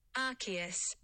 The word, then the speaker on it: Arceus: Arceus.